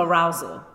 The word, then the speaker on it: arousal: Am I saying it right? Arisal? Arousal? – Arousal.